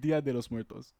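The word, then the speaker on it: Día de los Muertos: And it's that scene, Dios de los Muertos. Dio de los Muertos. I don't know how to pronounce it. – Dia de los Muertos.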